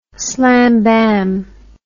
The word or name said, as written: slam bam